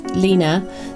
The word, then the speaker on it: Line: So the name of our podcast is based on a play on words, which is mainly my name, which is Lina, spelled L-I-N-E, and very often mispronounced as Line, particularly in Australia.